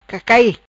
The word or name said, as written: Cacai